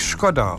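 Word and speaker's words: Škoda: Sie kennen doch das Auto Skoda, ne? – Hm? Ja. – Da ist ja ein kleiner Haken auf dem S. Deswegen, der Skoda wird ja eigentlich Skoda ausgesprochen.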